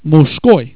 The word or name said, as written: muzhskoi